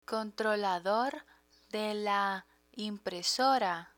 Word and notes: controlador de la impresora: Spanish

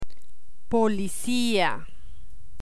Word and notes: policia: Spanish

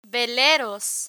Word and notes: veleros: Spanish

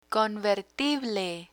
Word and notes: convertible: Spanish

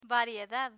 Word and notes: variedad: Spanish

